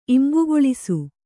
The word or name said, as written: ಇಂಬುಗೊಳಿಸು